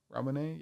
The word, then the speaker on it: Ramune: Ramune, yeah.